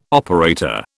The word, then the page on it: operator: British English